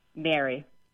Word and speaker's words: marry: And so let me ask you how you pronounce the word M-A-R-R-Y. – Mary. – And how do you pronounce the word M-E-R-R-Y? – Mary. – And do those sound the same to you? – I guess Mary with the E sounds maybe a little bit shorter.